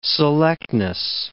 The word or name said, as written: selectness